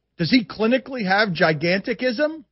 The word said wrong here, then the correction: gigantism: I don't know. – Is that even, am I even pronouncing the disease correctly? – No, I think he's too lanky to have gigantism, because typically, like, Andre the Giant was way shorter than Bob.